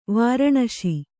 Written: ವಾರಣಶಿ

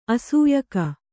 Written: ಅಸೂಯಕ

